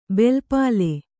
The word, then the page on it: ಬೆಲ್ಪಾಲೆ: belpāle; Kannada